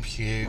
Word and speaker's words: Pugh: Pugh. – Are we saying that right? – Pugh. – I don't want to say Pug. – No, it's definitely not Pug.